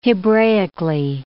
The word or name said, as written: Hebraically